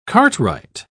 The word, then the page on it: cartwright: British English